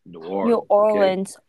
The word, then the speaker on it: New Orleans: I had to correct you before, but you're saying it right now. Before you say something, if y'all motherfucker keep saying New Orleans, God forbid, I might slap you. – Yeah, this is why I need correction, you know? – It's New Orleans, baby. – New Orleans. Okay? Wait, I have to do like how you say it. New Orleans, yeah, baby.